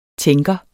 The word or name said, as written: tænker